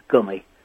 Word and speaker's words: Gumi: From your and my part of the world, I think it's Gummy. And they were... – It's Brummy. To rhyme with Brummy.